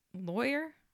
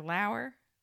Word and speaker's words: Lauer: It's either Lauer or Lawyer or Lauer. I think it's Lauer. – Okay, but it's spelled Lawer. So it's not like lawyer.